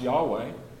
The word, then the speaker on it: Yahweh: We've heard YHWH, which is usually pronounced Yahweh.